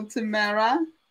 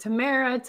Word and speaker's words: Tamara: Many people, there's so many ways to pronounce like Tamara. My mom happened to choose Tamara. So we'll go with Tamara.